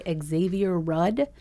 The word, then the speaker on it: Xavier Rudd: That is Honeymoon Bay by Xavier Rudd. Mm. Is that how you say his name? – Yes, very good.